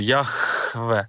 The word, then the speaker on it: Яхве: Тому що дійсно те, що сказав Бог Мойсеєві, Яхве, І так по-особливому вимовлялося це ім'я Боже, Яхве. Це був наче такий на продиху, це слово треба було казати.